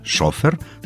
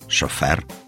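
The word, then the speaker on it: шофёр: А после Октябрьской революции слово, ну, скажем так, ушло в народ, и ударение перенеслось на второй слог. Было «шофер», стало «шофер».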